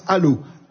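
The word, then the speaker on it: Allauch: D'abord une précision, le nom de la commune s'écrit A2L-A-U-C-H, et ça se prononce Allo, A2L-A-U-C-H.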